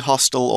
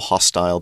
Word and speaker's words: hostile: And you can say hostile or hostile, both pronunciations That's are okay.